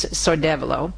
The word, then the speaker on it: Sordevolo: All right, speaking of events, so next year, in the little teeny gorgeous town of Sordevolo, I always pronounce it wrong, it's Sordevolo, and this is way up north in Piedmont.